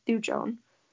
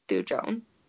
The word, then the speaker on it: thujone: So absinthe contains wormwood, which is like an herb, and then wormwood contains thujone. I think that's how you say it. It's T-H-U-J-O-N-E.